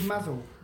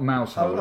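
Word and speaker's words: Mousehole: I think it's Mazel. I'm not Cornish. – It's definitely not Mouse Hole.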